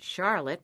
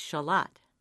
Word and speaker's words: Charlotte: Newcomers are usually surprised to learn that the name of one Vermont town, spelled like the southern city or a woman's name, is not pronounced Charlotte with a prominent R, but rather, you know someone's been here a while when they refer to the little town tucked between Route 7 and the shore of Lake Champlain as Shalott.